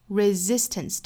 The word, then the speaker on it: resistance: Braveness is the resistance resistance 这个词里面第一个这个 s 呢应该是读 z